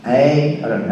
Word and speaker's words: Aaron: Aaron, huh? – Good pronunciation.